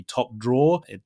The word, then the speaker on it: top drawer: And of course, it's like when Spanish speakers see that particular word, they're probably in many cases are very tempted to kind of pronounce that ER at the end, like drawer, right? – Yeah, but actually, it's the ER at the end there is completely silent. So it'd be top drawer, just like the verb, the dibujar, right? – Exactly.